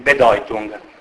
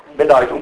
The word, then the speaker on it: Bedeutung: Questo è femminile, Allora, il nesso EU in tedesco si legge OI, mi dispiace per voi, ma... quindi Bedeutung, o Europa, in tedesco così dico. Bedeutung,